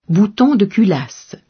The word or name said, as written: bouton de culasse